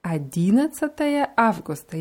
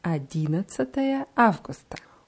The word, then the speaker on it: 11 Августа: Adinatsataye Augusta. – 11 Августа. Ja, die Betonung ist auf dem ersten I. 11 Августа.